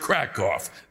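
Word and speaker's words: Kraków: It's pronounced Krakow. There are no cows here.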